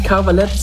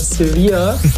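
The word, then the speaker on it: Cervelat: Die Schweizer Wurst sehe ich nicht Cavalette, sondern Sevilla. Es klingt ein bisschen wie diese spanische Stadt im Süden Spaniens.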